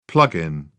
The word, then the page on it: plug in: British English